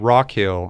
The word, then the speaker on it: Rockhill: So again, my name is Konor Rockhill, and that's spelled K-O-N-O-R. Very odd spelling. Thank my dad for that one. And then Rockhill, R-O-C-K-H-I-L-L.